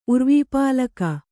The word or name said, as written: ಉರ್ವೀಪಾಲಕ